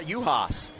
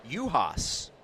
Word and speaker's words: Yuhasz: Yeah, Juhas. It is spelled Y-U-H-A-S-Z, but pronounced Juhas.